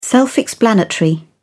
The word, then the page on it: self-explanatory: British English